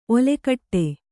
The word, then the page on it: ಓಲುಕೊಡು: ōlukoḍu